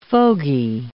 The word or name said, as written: fogy